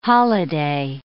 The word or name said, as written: holiday